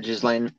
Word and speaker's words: Ghislaine: Ghislaine Maxwell or whatever. – Yeah, Ghislaine Maxwell.